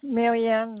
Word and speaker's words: Marianne: Yeah, Marianne Lettery.